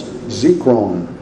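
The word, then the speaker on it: zikron (זכרון): It's from the Hebrew word that we would pronounce zikron, Z-E-K-R-O-W-N, if we were to transliterate it into English.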